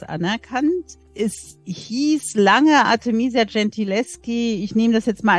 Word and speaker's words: Gentileschi: Bestes Beispiel, Sie haben vor kurzem auch erst ein Buch drüber geschrieben, die Artemisia Gentileschi. Ich hoffe, ich habe es richtig ausgesprochen. – Nicht ganz. Okay. Gentileschi halt.